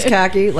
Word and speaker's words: Kaki: my name is Kaki, like the pants, Urch, rhymes with church.